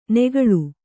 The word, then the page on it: ನೆಗಳು: negaḷu